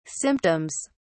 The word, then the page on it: symptoms: British English